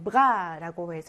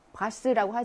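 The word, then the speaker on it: bras: 자, 1번에 bras라고 해서 팔이라고 하는 단어인데 이것이 발음이 되지 않고 있네요. 그렇죠? bras라고 하지 않죠?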